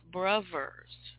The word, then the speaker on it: Brovers: It's Joe Brown and the Brovers, spelled with two V's, V as in Victor, Brovers, like a three-year-old would say it, you know, Brovers.